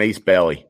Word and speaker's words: Ace Bailey: This is an Ace Bailey. Did I get it right, Jane? I didn't butcher that name, right? – That's how you say it, yeah.